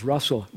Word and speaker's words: Russell: The muscle. You know, everybody always, when I tell them my name, you know, my name's Russell. Rhymes with.